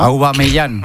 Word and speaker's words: Aubameyang: Aubameyang, ¿no? – Aubameyang.